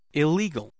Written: illegal

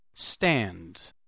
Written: stands

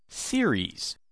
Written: series

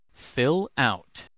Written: fill out

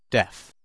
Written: deaf

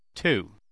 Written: too

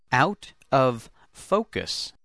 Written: out of focus

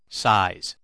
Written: size

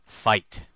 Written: fight

